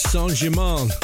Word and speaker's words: Saint-Germain: It's pronounced Saint-Germain.